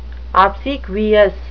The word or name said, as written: obsequious